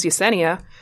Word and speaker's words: Yessenia: And when I say my name, hi, my name is Yessenia. His response was, oh, I'm going to call you Yosemite, like the national park.